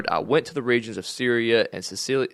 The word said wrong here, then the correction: Cilicia: Did I say that right? – Cilicia. – Cilicia.